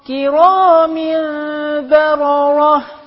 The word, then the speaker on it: كرام بررة: النون مش الباء نقلب النون ميم وبننطق الباء بعدها فقول كرام كرام يبقى انا نطقت ميم والميم الثانيه مخفاه كرام كرام دي بقى ميم بغنه مع الاخفاء خدت بالك